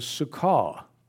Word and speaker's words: sukkah: The Hebrew for tabernacle is sukkah. It's spelled S-U-K-K-A-H in English.